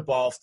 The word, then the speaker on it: Balfe: Katrina Balt, I think I'm messing with it. Yeah, it's Katrina. It's spelled in a very traditionally Irish way, I guess. Katrina Balfe, the lead.